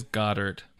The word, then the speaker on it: Goedert: I looked it up. It is Goddard.